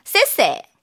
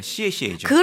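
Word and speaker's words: 시에시에 (谢谢): 세세가 아니라 시에시에죠. – 그렇죠. 네. 정확했어요. 시에시에. – 시에시에인데 시에시에.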